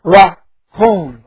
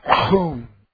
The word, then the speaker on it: rachum: It's the word rahum, R-A-C-H-U-M, rahum.